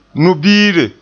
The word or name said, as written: nu'ubiire